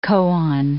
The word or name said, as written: koan